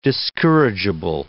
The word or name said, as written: discourageable